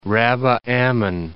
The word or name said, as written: Rabbah Ammon